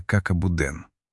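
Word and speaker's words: caca boudin: «Как и будин» произносится «как абуден».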